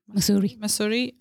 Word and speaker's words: Mussoorie: Mussoorie, in Uttarakhand.